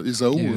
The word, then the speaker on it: Ésaü: On prononce Ésaü.